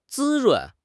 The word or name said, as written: 滋润